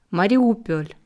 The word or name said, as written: Mariupol